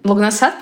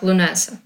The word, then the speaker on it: Лугнасад: Лугнасад, правильное ударение? Да. да. – Всегда... Ну, на русском считается, что да, русифицировали mm-hmm. как бы в среднем так. Хотя, конечно, на английском звучит как Лунеса.